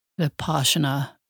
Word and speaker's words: Vipassana: Vipassana is the proper way to say it, in 2012.